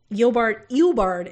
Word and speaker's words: Eobard: The backstory is revealed in which Eobard is how it's pronounced.